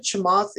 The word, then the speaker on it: Chamath: Him and Chamath, am I saying that right? – Yeah.